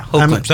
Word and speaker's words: Hoechlin: We have, I'm going to butcher this guy's last name, Tyler Hoechlin. – Yeah, Hoechlin. I think that sounded perfect.